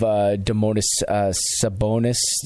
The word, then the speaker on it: Domantas Sabonis: They did get rid of Domantas Sabonis.